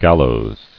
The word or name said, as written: Gallows